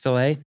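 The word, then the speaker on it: filé: They are ground up, and the ground up leaves are called filet, or it's actually spelled F-I-L-E, but would be pronounced filet.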